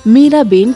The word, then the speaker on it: मीरा बेन: गुजराती में इसका उच्चारण मीरा बेन किया जाता है